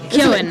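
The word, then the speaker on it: Keoghan: Keegan. Isn't it? – Keegan.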